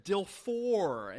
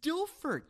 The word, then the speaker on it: Dilfer: He pronounced it Trent Dilfor, and he was like, Dilfor. It's like, it's Dilfer.